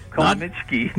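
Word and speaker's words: Comăneci: Nadia Comaneci. – Yeah, Comaneci.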